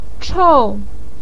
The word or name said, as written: chou4